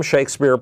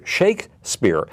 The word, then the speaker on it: Shakespeare: Let's just take the two A's in the word Shakespeare. – Uh-huh. – Each one is pronounced differently. Shake versus spear, one you don't even hear.